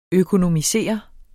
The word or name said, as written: økonomisere